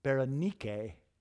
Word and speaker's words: Berenice: Berenice is a Greek name, and the Greeks pronounce it Berenike. And just like with Veronica from last week, you can hear that part of Berenice's name is the word Nike, which is Greek for victory.